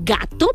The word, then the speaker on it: Gatto: Y digo gato porque está escrito como se escribe en italiano, que es con doble T. Y cuando lleva doble T, se tiene que hacer más fuerte la pronunciación, ¿ok? Gato.